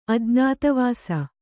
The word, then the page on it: ಅಜ್ಞಾತವಾಸ: ajñātavāsa